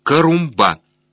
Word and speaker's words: Карумба: Оттуда ты на самолете местной авиалинии отправишься в местечко, называемое Карумба, с ударением на последнем слоге.